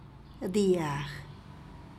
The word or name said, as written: dyag